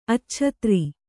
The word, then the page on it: ಅಚ್ಛತ್ರಿ: acchatri